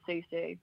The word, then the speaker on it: Susu: Now, what am I pronouncing this correctly? Susu savings or is it Sao Sao savings? – I think it's Susu.